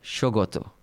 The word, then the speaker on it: Saugata: So how do you pronounce that word? – Shogoto. – Shogoto Majumdar.